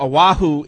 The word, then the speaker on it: Oahu: How do you pronounce Oahu? – That? Oahu in Hawaii.